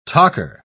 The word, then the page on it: tocher: British English